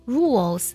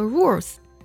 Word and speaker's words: rules: 另外两个单词 rules 规则这个单词呢要注意不能读作 rules，不能卷舌，它是个舌侧音重音收尾的。